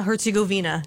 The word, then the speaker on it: Herzegovina: And they're taking on Bosnia Herzegovina.